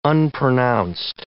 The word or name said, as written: unpronounced